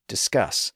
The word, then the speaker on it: discus: And he gave examples like pass, grass, bliss, and cross, as well as multisyllable words like finesse and discuss, which have the stress on the final syllable. By the way, notice the difference between discuss and discus, as in a small round object that you throw.